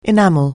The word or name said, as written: enactment